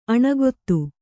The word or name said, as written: ಅನುಗತಿ